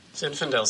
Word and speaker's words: Zinfandel: How do you say that? Zinfandel? Is that right? – Zinfandel sounds right.